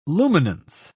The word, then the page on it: luminance: British English